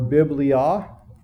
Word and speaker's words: biblia: The Greek word for book or books is there you can see the Greek is that you pronounce it biblos in the singular or biblia in the plural.